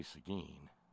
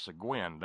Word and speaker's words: Seguin: See, I know how to say Seguin. I'm going to throw a fit at the first person who says, no, that's pronounced Seguin down here, Bob.